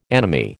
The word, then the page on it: anomy: American English